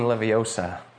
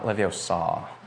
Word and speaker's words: Leviosa: It's Wingardium Leviosa, not Leviosa.